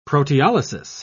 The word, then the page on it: proteolysis: British English